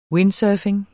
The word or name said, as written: windsurfing